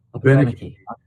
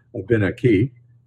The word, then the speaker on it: Abenaki: Abenaki. – The emphasis is usually on the third syllable from the end, Abenaki.